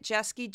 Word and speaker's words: Jeske: It's my name, just Juliette Jeske, J-E-S-K-E.